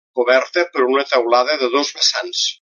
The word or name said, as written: vessants